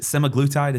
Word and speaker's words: semaglutide: Is there a connection between, is it semaglutide? Is that how you say it? – Yeah.